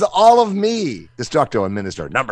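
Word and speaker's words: MxMxSxTxRR: So Minister, not Monster. Minister.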